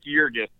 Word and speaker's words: Yerges: Alex Yerges, but with just like the, you can forget the Alex part. You call me Yerges, it works out fine.